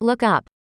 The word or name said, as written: look up